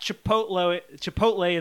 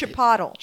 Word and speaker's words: Chipotle: So I use the promo code at Chipotle, and they give me Chipotle. It's pronounced Chipotle.